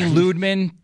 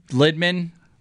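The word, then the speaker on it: Lydman: It's always Ludman and Talander. Those two are the best defensive pairing in my childhood. – It was Lidman and Talander when they first got here, and then it eventually evolved into Talander and Ludman.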